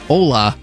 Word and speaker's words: Hola: No, it's hola. – Oh, it is hola? – Did you just say hola? – Cuz it's O-L-A. Hola. Hola. – It's like hola but with a silent H.